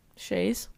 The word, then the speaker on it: Chazes: Silent Z. – C-H-A-Z-E-S? – Shays. – Oh, okay. Shays. Okay. – Or Shayaz. – Abby does Shayaz. – Day. – D-E-S.